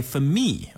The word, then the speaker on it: F3MIII: It's by Femi. Okay, that's spelled F3MIII. I know I said spelled and then said a number, but hey, man, that's how you say his name.